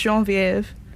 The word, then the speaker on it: Genevieve: Yeah, my name is French. – Is that it? – Yeah, it's pronounced Genevieve. – Oh, wow. – Yeah, and Genevieve.